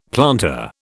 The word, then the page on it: planter: British English